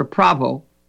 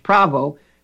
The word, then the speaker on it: Prawo: And the P stands for Prawo. The W is pronounced V in Polish. Prawo, P-R-A-W-O-E, means and.